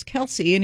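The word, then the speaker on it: Kelce: That we've all been saying Kelsey wrong? – Yeah, right? – Apparently, it's Kels. – But, the whole world says it, Kelsey.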